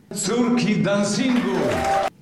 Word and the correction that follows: Córki Dancingu: Pronounce the Polish, please. – Córki Dancingu. – No. But yeah, when they say the name of the band in Polish, Córki Dancingu.